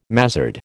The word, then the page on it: mazzard: American English